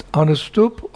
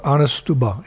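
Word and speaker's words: Anuṣṭubh: It's Anustub or Anustuba. It depends how you're what you're saying it for.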